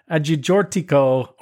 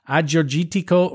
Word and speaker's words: Agiorgitiko: Gaia 14 to 18 H Agiorgitiko Rosé.